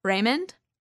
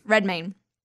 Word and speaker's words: Redmayne: Raymond. – Redmayne.